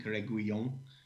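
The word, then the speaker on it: Reguilón: Personally, United have been heavily linked with, I'm sure you'll correct me on the pronunciation, Reguillon. – Well, it's as good as I've got, I think, Reguillon. He's got the double L, so that's normally a U sound.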